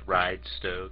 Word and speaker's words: Ridisto: Okay, we'll move back to Ridisto. – Can I just say first that it's pronounced Ridesto, kind of like two words, Ridesto. Anyway, Ridesto, sorry.